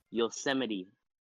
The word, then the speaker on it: Yosemite: I was about to say Yosemite. – Yosemite. – Is that not how you pronounce it? Is it not how you pronounce it, or am I just illiterate? – Yosemite.